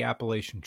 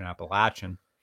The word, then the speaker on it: Appalachian: And I remember that verse in that song because I help out with the Appalachian Trail. Mm. Excuse me, the correct pronunciation, Appalachian. With that,